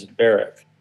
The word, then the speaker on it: Berwick: Oh, well, we showed up at North Berwick, which is spelt like Berwick. Only they say it as Berwick in Scotland.